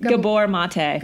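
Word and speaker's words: Gabor Maté: Gabor Mate.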